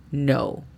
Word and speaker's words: know: La pronunciación fonética de esta frase sería A-S para as, Y-U para you, N-O-U para know, as you know.